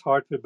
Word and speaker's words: Hertford: It's pronounced Hartford, but it's spelled H E R T F O R D at Oxford.